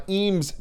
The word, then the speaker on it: Eames: Eames chairs, as we've been told they're pronounced.